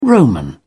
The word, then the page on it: roman: British English